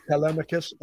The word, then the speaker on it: Telemachus: The goddess tells Telemachus, am I pronouncing that right, Dennis? – Mm-hmm. – Telemachus, okay. Yep.